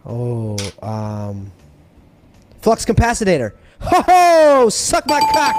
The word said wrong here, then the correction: Flux Capacitor: That's not how you say it. It's the Flux Capacitor.